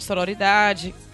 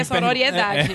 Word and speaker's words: sororidade: É, ela fala muito do feminismo, fala de sororidade. Falei certo? – Sororidade. É. Eu sororidade. sempre acho que, eu sempre acho que é sororiedade.